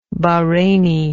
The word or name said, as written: Bahraini